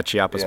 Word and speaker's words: Chiapas: Yeah, Chiapas, yeah.